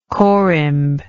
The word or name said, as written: corymblike